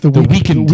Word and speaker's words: The Weeknd: It's The Weeknd. – The Weeknd. The Weeknd. – He's missing an E. – It's not The Weeknd. – That's what everybody says, but it's spelled W-E-E-K-N-D.